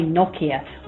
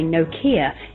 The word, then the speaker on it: Nokia: The British will say Nokia, which is a fine whisper away from what's said in Finland. If you say Nokia, then you are a country mile from the Finnish head office pronunciation.